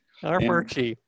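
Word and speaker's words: Armuchee: Armurchie. – Yeah. – There's a silent R in there somewhere. I guess an invisible R that you're.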